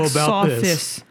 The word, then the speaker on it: Sothis: I'm sure it's pronounced like Sothis. – Sothis. Yeah, it's spelled so this though. – Yeah, it's so this.